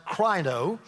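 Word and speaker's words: krino (κρίνω): It's a Greek word that's pronounced krino, and it literally means to discriminate, or it means to make a difference.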